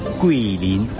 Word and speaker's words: Guilin: Esta ciudad se llama Guilin.